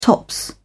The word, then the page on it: top: British English